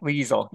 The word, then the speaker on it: Liesel: Well, because the German pronunciation is the hard e. – Okay. – Liesel.